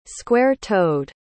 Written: square-toed

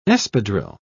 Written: espadrille

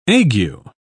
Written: ague

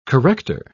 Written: corrector

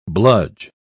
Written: bludge

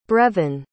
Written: brevyn

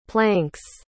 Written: planks

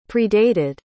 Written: predated

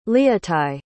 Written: leotie

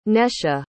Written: Nesha

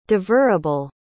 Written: devourable

